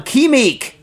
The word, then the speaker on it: Qimiq: Okay, Alan Sternberg writes in on Quimik, which is spelled Q-I-M-I-Q. Dumb, right? Q-I-M-I-Q.